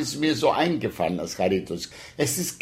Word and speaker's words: Charitos: Charitos. – Charitos. – Charitos. Also die Betonung kommt auf das I, Charitos.